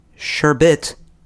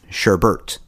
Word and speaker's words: sherbet: Insist on calling sherbet, sherbert, even though there's not an R in the second syllable anywhere.